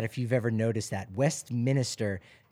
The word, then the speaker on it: Westminster: It's Westminster, not Westminster. It's not minister. – Oh, no. – It's Westminster.